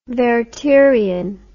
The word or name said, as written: wertherian